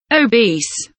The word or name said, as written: obese